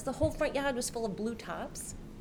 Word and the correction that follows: tarps: Am I saying that correctly? Tops. – Blue tops. – You know, the blue plastic tops. And that. – Tarps. – Tarps. – Tarp. – Okay, tarp. Tarp.